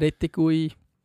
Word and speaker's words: Retegui: Nein, darum, ich habe jetzt, wie heisst der Retegui, spricht man so,